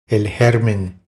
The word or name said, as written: El germen